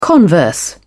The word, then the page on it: converse: British English